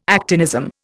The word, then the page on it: actinism: Indian